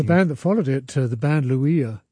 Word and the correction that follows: Lula: Is that how you pronounce that? – Lula. The band Lula. – Oh, Lula.